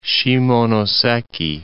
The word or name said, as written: Shimonoseki